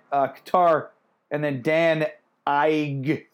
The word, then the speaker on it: Ige: Dan Ige, that's how you say it, by the way. Uh, Ige. No, it's Ige.